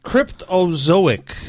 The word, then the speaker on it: Cryptozoic: Cryptozoic. That might actually be right. – Cryptozoic? Did you actually say that? Oh my God, I think you actually said it right.